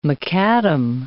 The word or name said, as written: macadam